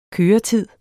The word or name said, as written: køretid